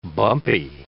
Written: bumpy

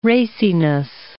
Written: raciness

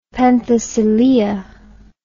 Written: Penthesilea

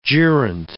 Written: gerent

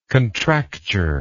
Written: contracture

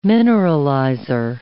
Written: mineralizer

